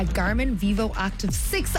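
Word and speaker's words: Garmin Vivoactive 6: A Garmin Vivo Active 6.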